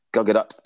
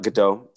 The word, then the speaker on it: Gadot: You're meant to enunciate the T, yes? Gal Gadot, it's not Gadot.